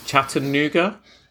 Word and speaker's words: Chattanooga: So you're originally from Tennessee, from a place called, now forgive me if I've mispronounced it, Chattanooga? – That's the one.